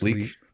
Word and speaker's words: lychee: L Y C H E E. Do you know how to say it, Thomas? – No. – No. Nobody, le- does. Nobody, does. nobody does. Nobody does. Leech. No, not a leech like on your bike. Leech. It's like this little ball and it's like a giant grape.